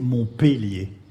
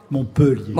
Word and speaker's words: Montpellier: Merci de prononcer Montpellier. – J'ai dit quoi ? – Montpellier. – Bon, là, Montpellier. C'est décidément, désormais, je dirais Montpellier.